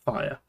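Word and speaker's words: Thire: So, now this probably is the most recognizable Coruscant Guard member, and it is Clone Commander Fire, but it's spelled Thirie, but it's pronounced Fire, and he was CC 4477.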